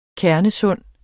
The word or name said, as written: kernesund